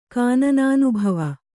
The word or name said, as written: ಕಾನನಾನುಭವ